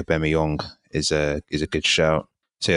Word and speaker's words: Aubameyang: Shout out to Adrien Aubameyang. That's how you pronounce his last name.